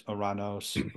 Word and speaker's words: Uranos: And in Hebrew it's Shemayim, in Greek it's Uranos, where we get the word Uranus. So I use the more Greek pronunciation Uranos to avoid that, but to avoid.